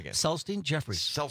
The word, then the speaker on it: Celestine Jeffries: Celestine Jeffries. – Celestine. I mean, you worked with Julie, but I still always got your name wrong. Always. Celestine. Okay. – Accent on the first syllable.